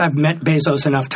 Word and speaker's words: Bezos: And do you think I don't know when Jeff Bezos how do you pronounce that? – I think it's Bezos.